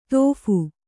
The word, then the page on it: ತೋಫು: Kannada